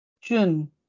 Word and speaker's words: Jun: And Jun. Is that correct? – Yeah. – Did I say that correctly? – Yeah, correct. It's Jun. Yeah. Same like the month.